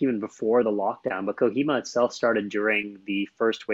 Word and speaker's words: Kohima: How do you pronounce it? Kohima? – Kohima, yeah.